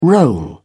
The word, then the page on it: roll up: British English